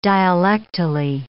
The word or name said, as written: dialectally